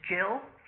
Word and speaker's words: Gill: Well, I have copies here, and if you wanted a copy, you would have to address your request to Jill Foss. Jill is spelt with a G like George, G-I-L-L, and my last name is Foss, F for Freddy, O for Orange, S for Sam, twice.